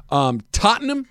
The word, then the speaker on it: Tottenham: Tottenham. Did I say that right? Is that the right way to say Correct. – That? – Tottenham.